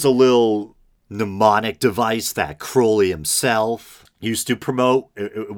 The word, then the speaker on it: Crowley: A lot of people say Crowley. I believe the proper pronunciation is Crowley.